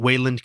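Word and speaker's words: Weiland: I think I pronounce it Wayland because of Wayland Smithers from The Simpsons. – Yeah, but I feel it would be spelled differently, but we'll go with Wayland.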